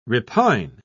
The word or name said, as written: repine